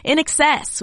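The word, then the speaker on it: INXS: That's a classic Australian band whose name is not spelled how it's pronounced and not pronounced how it's spelled. In Excess with the 1982's Don't Change here on Studio One, right after Car Seat Headdress brought us Nervous Young Inhumans.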